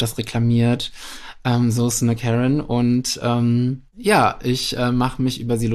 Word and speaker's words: Karen: Karen, die kommt eigentlich aus dem englischen Sprachgebrauch und wird Karen ausgesprochen dort.